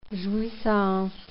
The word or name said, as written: jouissance